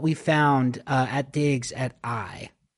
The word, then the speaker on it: Ai: And it is the city of Ai. Now, again, in the Bible, when we read that, it's spelt AI, and it's not artificial intelligence, but it's pronounced Ai.